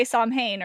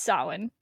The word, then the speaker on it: Samhain: Because I know a lot of people say Samhain or something like that, but no, no, no, no, it's Samhain.